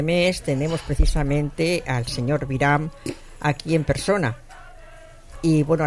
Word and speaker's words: Biram: la libertad del diputado Biram, se pronuncia así, Biram. – Sí, Biram. Sí.